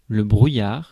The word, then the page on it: brouillard: France; [bʁu.jaʁ]